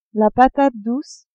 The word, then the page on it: patate douce: female